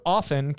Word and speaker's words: often: The word often can be pronounced with a silent T, the more common pronunciation, or with an audible T.